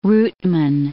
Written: routeman

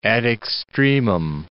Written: ad extremum

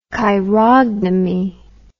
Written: chirognomy

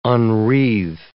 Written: unwreathe

